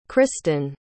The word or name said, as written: cristin